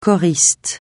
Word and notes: choriste: français

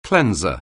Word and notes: cleanser: anglais

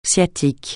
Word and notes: sciatique: francais

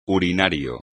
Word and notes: urinario: espagnol

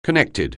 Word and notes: connected: anglais

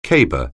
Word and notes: caber: anglais

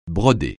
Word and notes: broder: francais

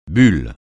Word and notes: bulle: francais

